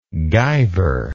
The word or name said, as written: guyver